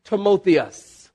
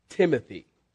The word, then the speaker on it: Timotheus: One of those certain disciples would have been Timotheus. That's his Greek pronunciation. We pronounce it Timothy.